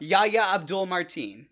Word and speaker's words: Yahya Abdul-Mateen: Yahya Abdul-Mateen.